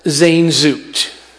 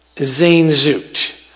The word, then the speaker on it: Sehnsucht: Germans have a word pronounced Sehnsucht. Sehnsucht.